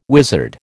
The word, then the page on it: wizard: American English